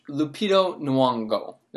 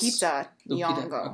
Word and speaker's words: Lupita Nyong'o: Miss Lupita Nyong'o. Did I say her name correct? – No. – Close? – Lupita Nyong'o.